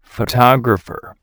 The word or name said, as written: photographer